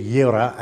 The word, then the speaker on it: Jireh: Jehovah Jireh, say it with me, Jehovah Jireh, or the name can be Yireh as you pronounce it in Hebrew.